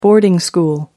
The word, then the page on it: boarding school: American English